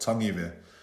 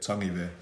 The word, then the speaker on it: Tangive: Yeah, so my full name is Ratu Apolosa Tangive. So that's the correct pronunciation. The easiest way that I explain to people, you just say tongue as in your tongue, and then Ive, Tangive.